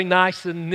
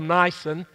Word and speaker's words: Nisan: Of Esther chapter 3, in the first month, which is the month of Nisan, some say Nisan, Nisan, hey, it's up for grabs. I suppose it depends upon the part of the country that you're in, how you pronounce these. I'll pronounce it the month of Nisan.